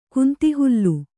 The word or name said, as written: ಕುಂತಿಹುಲ್ಲು